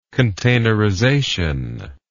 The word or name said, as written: containerization